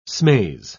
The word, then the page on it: smaze: British English